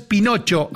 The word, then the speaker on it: Pinocho: Según su acentuación, ¿qué tipo de palabra es pinocho? ¿Aguda, grave o esdrújula? – Esdrújula. – No, tiempo grave. – Pinocho. – Grave. Pinocho es grave. ¿Grave? Tiene el acento en la anteúltima sílaba, como todas las palabras grave.